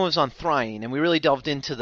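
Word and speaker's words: Thráin: I think what we came down is Thrain. I think the sort of emphasis on the second syllable, it's 2 syllables, and the emphasis on the second syllable is undisputed.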